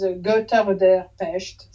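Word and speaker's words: Götter der Pest: Goetter, I think that the right pronunciation is Goetter der Pest, something like that. Okay. In Deutsch.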